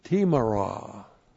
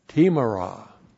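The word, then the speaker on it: timarah: Timara. T long I M A R A H. Timara.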